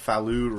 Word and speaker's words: Falu: I'm actually a color called Falu Red. – Okay. – That's spelled F-A-L-U.